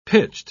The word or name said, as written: pitched